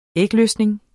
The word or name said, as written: ægløsning